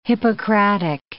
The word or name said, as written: Hippocratic